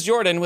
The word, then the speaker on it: Jorphdan: My name is Jordan with a silent PH in the middle, and apparently I'm green, is what Lucian says on his chat.